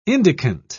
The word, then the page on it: indicant: British English